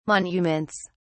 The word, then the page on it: monuments: British English